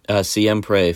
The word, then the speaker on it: Siempre: Semper Familia? – Siempre Familia.